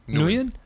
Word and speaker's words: Nguyen: How do you pronounce N-G-U-Y-E-N? Nguyen? – Nguyen? – Nguyen? – Nguyen. – Nguyen. Bao Nguyen.